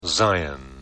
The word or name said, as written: Zion